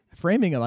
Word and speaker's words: Framingham: Framingham. I got a Framingham is how they say it up there.